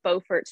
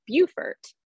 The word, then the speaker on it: Beaufort: So the series that I'm currently writing through is the Beaufort Poker Club, based in Beaufort, North Carolina. And the funny thing about it is there's also a Beaufort, South Carolina, spelled the exact same way, but they pronounce it as Beaufort.